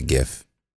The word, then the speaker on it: GIF: And I heard that it could be pronounced GIF, but I pronounce it GIF. – It is pronounced GIF. It's basically gift, like in present, without the T. I've heard GIF. I've heard GIF. It's GIF. G-I-F. GIF, but the T is silent.